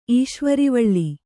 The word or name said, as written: ಈಶ್ವರಿವಳ್ಳಿ